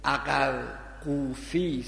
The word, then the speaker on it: عقرقوفی: شعیب ابن یعقوب میگن همون شعیب ابن عقرقوفی است، اینو قافره عقر قوفی، قاف قاف و واو و ف و ی، خیلی تلفظش غلط میخونن.